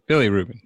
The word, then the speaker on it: bilirubin: Bilirubin, is that really how you say it? – Bilirubin, yeah.